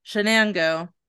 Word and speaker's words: Shenango: I did the Google pronunciation for this. And I was like, don't mess it up. Shenango. That's what it is.